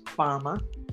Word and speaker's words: Farmer: Again, my name is Margaret Farmer. And Margaret is spelled M-A-R-G-A-R-E-T-T-E. And everybody knows how to spell Farmer, I'm sure. F-A-R.